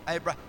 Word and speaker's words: Abraham: And when you put that H in their names, it always, if you say it correctly, it sounds like wind. Abraham.